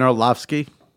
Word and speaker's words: Orlovsky: So, guys like Romo or Dan Orlovsky, how do you say his name? – Dan Orlovsky. – Orlovsky, sorry. Yeah.